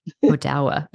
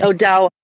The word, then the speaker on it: Odawa: And you did a great job pronouncing it, Little Traverse Bay Band of Odawa Indians. – Odawa. Okay. – Odawa.